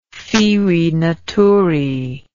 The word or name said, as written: ferae naturae